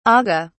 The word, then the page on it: agha: British English